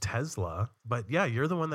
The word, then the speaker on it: Tesla: I recently purchased a Tesla Model 3. And you pronounced it correctly. I'm very proud of you.